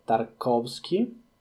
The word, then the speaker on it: Tarkovsky: Film dell'83 è diretto da Tarkovsky, si pronuncia così? – Sì.